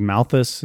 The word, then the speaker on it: Malthus: I say Malthusian because it was, I think it was Malthus, is that how you say it? Mm-hmm.